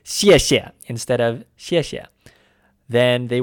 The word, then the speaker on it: 谢谢: That's the same character pronounced 谢 in the fourth tone repeated twice. And just like with mama, the second repetition is pronounced toneless. So to say thank you, we get 谢谢. Fourth tone, no tone.